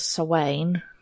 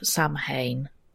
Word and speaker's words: Samhain: So the other thing that arrived was Sawain, or if you want to read it as it's spelled, Samhain. If you want to go and Google it, but it's not pronounced that way.